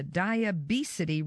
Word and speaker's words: diabesity: And the information contained in the rye, a phytonutrient called lignin, switched on the diabesity reversing genes. And I said that correctly, diabesity. It's a combination of diabetes and obesity.